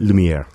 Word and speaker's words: Lumiere: Bob Brosman Orchestra is the artist of this disc, and the title is Lumiere. Did I pronounce it? – Lumiere. – Okay. – Which is French for light.